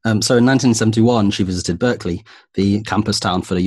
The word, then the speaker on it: Berkeley: In 1971, she visited Berkeley, the University of California, Berkeley? – Yeah, is that how you pronounce it, Berkeley? – That is how you pronounce it, Berkeley.